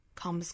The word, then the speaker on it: comes: 再补充一小点哈，就是 calm 这个单词后面如果加上了 s，这个 s 的发音呢，应该是一个浊音的 z 的发音，comes。但是呢，因为这个字母是在单词尾部，一定要把它轻弱下来，不要发成 comes，而是要把它浊音轻化，弱回来，读成 comes。